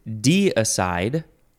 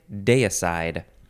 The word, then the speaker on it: deicide: This word is de-aside or day-aside. It is spelled, it's almost spelled like decide. D-E-I-C-I-D-E. Deicide, deicide. Noun from 1577. I think I'm going to say deicide.